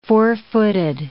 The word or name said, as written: four-footed